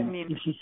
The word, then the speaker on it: Nin: She had a Spanish Anais? – Last name. – Yeah, Nin.